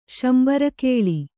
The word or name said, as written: ಶಂಬರಕೇಳಿ